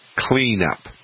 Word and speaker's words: Kleenup: Now, the chemical version is called Clean Up, and it's spelled K-L-E-E-N-U-P, Clean Up.